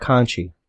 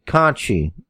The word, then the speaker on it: Conti: And, Tainara Conti. – Tainara Conchi. – Tainara Conti. – Conchi. Yes. – It's spelled wrong. – No, it's Brazilian Portuguese.